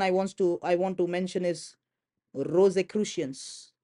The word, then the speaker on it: Rosicrucians: Rosicrucians. Rosicrucians. Let me spell that for you. Rosicrucians is spelled as R-O-S-I.